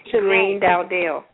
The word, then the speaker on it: Greene-Dowdell: And how do you pronounce your last name? – Okay, it's Shani Greene-Dowdell.